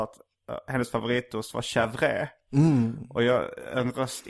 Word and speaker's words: chèvre: påpekar att det uttalas chèvre.